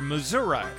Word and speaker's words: Missouri: debut single from the Ozark Mountain Daredevils, a country rock group hailing from Springfield, Missouri, or Missouri, as my mother pronounced it, being a native of the area.